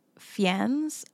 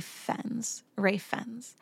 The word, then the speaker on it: Fiennes: Now, the guy who played Voldemort, his name looks like it's Ralph Fiennes, but I looked up how to pronounce it and it's like the opposite of safe. It's like Ralph Fiennes.